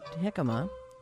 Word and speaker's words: jicama: How about, no, J is silent and jicama.